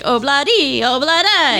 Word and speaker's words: Ob-La-Di, Ob-La-Da: Obladi oblada. Yeah. But it's obladi oblada, isn't it? Like obladi oblada.